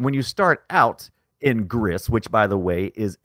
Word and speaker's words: Gris: You said it right, Gris, like Chris. It is. It apparently means gray.